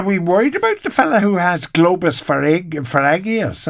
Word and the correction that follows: pharyngeus: I think he means he has globus pharyngeus, which used to be called globus hysterigus.